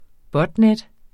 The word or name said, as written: botnet